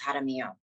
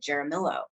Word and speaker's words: Jaramillo: My last name is pronounced Jaramillo. That's just how it's pronounced. People couldn't spell it. Nobody was rolling their R's. No one was having a silent J. And so I would really Americanize it and call it Jaramillo.